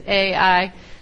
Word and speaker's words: Ai: That's why the English pronunciation is Ai. It's probably in Hebrew more like I or something like that, but we'll just call it Ai.